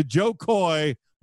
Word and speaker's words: Jo Koy: These are things that are essential to Joe Coy when he's out on the road, things that he cannot not travel with.